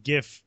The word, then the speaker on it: GIF: GIF fury that came out of it. I know it's pronounced GIF, but it's more fun to say GIF.